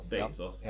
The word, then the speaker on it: Bezos: Ett lite angränsande tema så här egentligen, men jag läste ju också Amazon och Jeff jag vet inte om man uttalar Jeff Bezos efter, ja. Men det är Bezos eller? – Bezos.